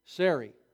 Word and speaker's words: Sarai: His wife, how was her name pronounced? Or anybody here, was it Sarai before it was converted to Sarah? Sarai. Okay, I want to make sure I get that right.